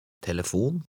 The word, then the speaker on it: telefon: In numbers 5, 6, and 7, words of foreign origin, the accent is on the last syllable. Read number 5. – Adieu. – 6. – Hotel. – 7. – Telefon.